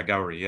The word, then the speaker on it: Gauri: To go back, the person at the center of your story is Gauri, is that how you pronounce it? – That's right. Yeah, Gauri, yeah.